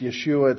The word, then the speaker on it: Yeshua: When Joseph called Jesus his name, it didn't sound like Jesus. It was actually pronounced Yeshua. It's actually more where we would get the name Joshua.